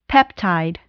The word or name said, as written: peptide